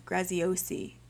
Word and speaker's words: Graziosi: Is his last name Grigetsky? Is that how you say his last name? Graziosi? Is that it? I always say his name wrong. Graziosi. I have it right in front of me.